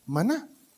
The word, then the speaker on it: maná: Que en hebreo se pronuncia maná.